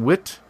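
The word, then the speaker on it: Witte: And this is the Amagang Wit, or is it? It's W-I-T-T-E. Is that white or wit? tea? How do you say that? – Wit. I would say wit.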